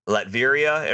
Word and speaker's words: Latveria: If they have Latveria and Ruler, that's how you say it.